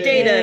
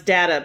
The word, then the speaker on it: Data: Data, nice job. Also sometimes pronounced Data, but that's not his fucking name.